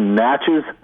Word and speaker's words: Natchez: It's actually Natchez, sort of rhymes with matches.